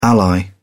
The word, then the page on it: ally: British English